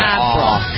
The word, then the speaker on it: Avril: Avril, instead of Avril. It's Av.